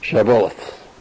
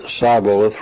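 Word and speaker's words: Shibboleth: If he said no, then they would say to him, say now, Shabboleth. But he said Sibboleth, for he could not pronounce it correctly.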